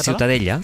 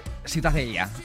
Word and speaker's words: Ciutadella: ¿cómo se pronuncia? – La Ciutadella. – Ciutadella. Bueno, pues Ciudadela también en castellano. – Ciutadella, sí. – Pues eso, ha quedado clarísimo. ¿Para qué se meten? – ¿Tú cómo lo pronuncias? – Ciutadella. – Muy bien.